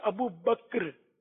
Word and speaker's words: ابو بکر: یہ ابو بکر یہ پڑھا جا رہا ہے, اصل تلفظ ہے ابو بکر, کاف جو ہے وہ ساکن ہے, اس لیے صدیق اکبر یہ تلفظ صحیح ہے.